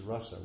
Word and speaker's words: rasa: Remember, their word is rasa with a short A.